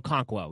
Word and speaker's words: Okonkwo: Okonkwo, yes.